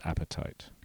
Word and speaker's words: apatite: The enamel surface is comprised mainly of a complex molecule called apatite. – Apatite? – The apatite that you get when you're hungry for lunch. This is spelled slightly differently. – A-P-A-T-I-T-E.